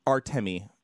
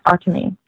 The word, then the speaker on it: Artemi: So I think we're talking about John Panarin, Antemi Panarin, who... – Artemi. – Thank you. – I think I'm pronouncing it right. I don't know. Sometimes I second guess my pronunciation of things. So I think it's Artemi.